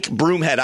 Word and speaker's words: Broomhead: My name is Mike Broomhead. I am in Phoenix, Arizona. If you are a social media user, you can find me at Broomhead Show on Twitter. Broomhead is spelled just like it sounds.